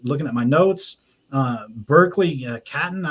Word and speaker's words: Catton: I'm not sure if I pronounced that right, but go ahead and take it away, Kyle. – Yeah, you got it. I actually pronounced his name because I play a lot of Chell. Every time his name popped up, I pronounced it wrong. – I almost said Cotton. That's why I kind of stumbled. – I pronounced it Catton. I pronounced it Catton for a long time.